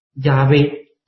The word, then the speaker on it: 亚威: 也有学者尝试是把阿拉美语的名字的这个音标，也就是舍玛移植在亚威，它成为是耶瓦。当然这个发音从语法的角度来说是没有问题的，但是这个也没有被普遍被接受。那今天普遍的被接受的发音方式就是亚威。